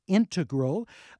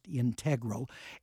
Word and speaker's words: integral: Natalie Chudnovsky found the authoritative pronunciation. It is integral, just like our listener said, not integral.